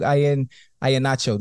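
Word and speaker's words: Ihenacho: Duke Ian Nacho. Did I say that right? – Yeah.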